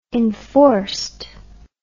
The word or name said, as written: enforcedly